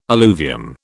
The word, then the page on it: alluvium: British English